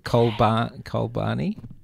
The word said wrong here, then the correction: Chobani: Is it Cholbani? Is that what it is? – Well, it's Chobani.